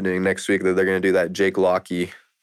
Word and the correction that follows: Jake Lockley: Or Lockley. How do I say it again? – Jake Lockley. Explain that.